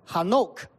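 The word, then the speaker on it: Hanok (חנוך): 以诺名字的在希伯来文的里面的这个发音叫 Hanok。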